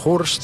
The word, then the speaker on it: Hurst: Básicamente el apellido es Hurst, ¿sí? H-U-R-S-T,